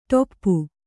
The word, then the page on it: ಟೊಪ್ಪು: ṭoppu; Kannada